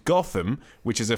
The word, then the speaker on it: Gotham: However, he says, I soon found out that it is pronounced Gotham by the locals, and I got disapproving looks when pronouncing it a la Bruce Wayne.